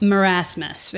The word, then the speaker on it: Marasmus: how do I say this? – Marasmus? – Marasmus. Very good, thank you.